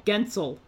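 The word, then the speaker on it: Guentzel: Yeah, all of a sudden you're 15 times Jake Gunsel. How do you say his name? – Gensel.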